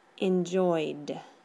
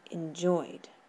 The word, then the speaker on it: enjoyed: Eso sería enjoyed. No es enjoyed, es enjoyed.